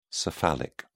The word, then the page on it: cephalic: British English